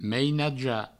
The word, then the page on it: mainajar: meinaja